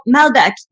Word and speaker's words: Malbec: So when I posted those, everybody would ask me about, like, okay, what about Malbec? Like, do you pronounce the C? Like, yes, you pronounce the C on Malbec.